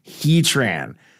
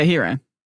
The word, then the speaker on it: Heatran: P.S. It's pronounced Heatran. – Yeah, Heatran.